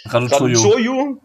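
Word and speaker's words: Răducioiu: 97, 98 kam dann Florin Radu, äh, scheiße, wie spricht man denn aus? – Raduchoyu, genau,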